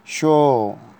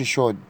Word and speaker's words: chaud: سواف يعني عطشان، فام يعني جوعان، شو يعني حران. طبعا ما نقولش شود، الدي لا تنطق.